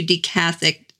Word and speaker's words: decathect: It's spelled D-E-C-A-T-H-E-C-T.